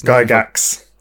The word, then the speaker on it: Gygax: Uh, but Gary Gygax, I believe he says Gygax. You, s- you pronounce the X.